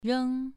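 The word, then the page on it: reng: tone 2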